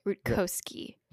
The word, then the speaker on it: Rutkoski: I think Rutowski, is that how you say it, or did you say it wrong and just make me say it wrong just now? – There's a second K. It's Rutkoski.